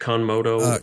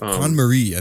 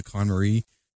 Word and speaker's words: KonMari: I've been following on your personal Instagram that you've been doing how do you pronounce it? Conmodo? – Con-Marie, I think.